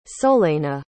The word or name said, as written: solaina